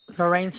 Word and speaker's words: Lorraine: Lorraine Salas, L-O-R-R-A-I-N-E, Salas, S-A-L-A-S.